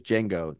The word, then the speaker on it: Django: And he goes, Django. The D is silent.